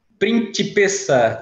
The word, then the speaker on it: Principessa: Principessa, com T, print, principessa.